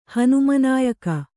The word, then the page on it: ಹನುಮನಾಯಕ: Kannada